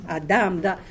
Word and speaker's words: Adam: All right, Adam. The stress is on the second or the last syllable, Adam.